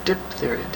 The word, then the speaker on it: diphtheria: Isn't it diff? Diphtheria. It's D-I-P-H. – Yes. – It? Diphtheria. – But most people pronounce it dip, which is the way we suggest it that way.